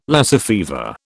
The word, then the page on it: Lassa fever: British English